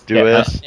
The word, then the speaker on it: Deus: Deus.